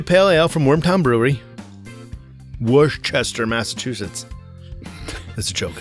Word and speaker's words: Worcester: No, the C is there. No, it's we get rid of the R, C, N, E. And just... – Yeah, it's Worcester. It's missing a whole syllable.